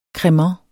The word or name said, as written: cremant